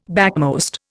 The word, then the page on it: backmost: Indian